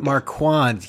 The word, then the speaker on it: Marquand: Is that how you say his name? – Marquand. That's just how I pronounce it.